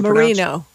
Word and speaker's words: Merino: Not Murano, you said, how's it Merino.